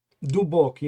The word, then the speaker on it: Dubok: Is that VSR Dubok? – Dubok, yeah. – Dubok, yeah. Okay, sorry Dubok if I mispronounce means, it.